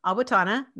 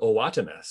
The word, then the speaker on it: Owatonna: Yeah, good job on the name pronunciation. My dad, who lives in Utah, still calls it Owatonna. So you did well.